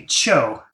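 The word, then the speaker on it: qiu: in Chinese, it's supposed to be Cho. – Hmm, okay. – But it's one of those things where it's counterintuitive, where if you go with the pinyin spelling, it's Q-I-U. – Okay, right. – Which is like, you know, it's not really intuitive how you'd pronounce that in English.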